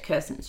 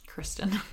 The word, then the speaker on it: Kristen: Cinderella, but it didn't really affect Kirsten Stewart's career. Kristen.